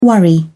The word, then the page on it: worry: British English